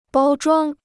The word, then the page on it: 包装: bāo zhuāng